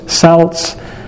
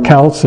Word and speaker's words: Celts: The Canaanites, the Babylonians, Assyrians, Greeks, Egyptians, Romans, Celts, or Celts if you like to pronounce it that way, the Norse, the Hindus, the Buddhists, the Aztecs, the American Indians, the Incas, and many other cultures have their many gods.